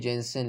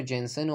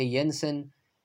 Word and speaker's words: Jenson: اسم جنسن اومدن به شکل اسکاندیناویایی دارن تلفظ میکنن یه جورایی شبیه این شکلی به نظر میرسه که ینسن